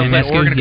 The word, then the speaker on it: Ionescu: The Serena Ionescu, yeah.